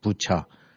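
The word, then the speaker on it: 부차 (Буча): 그 중에서 이제 하나가 지금 대학살 거기 벌어지고 있, 그런 흔적이 발견됐다는 이제 부차 영어식 발음은 이제 부카라고 하던데 음.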